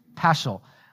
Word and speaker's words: Paschal: How do I say that word? I think, or Paschal, Paschal. Thank you. I was looking at you. Thank you. Paschal.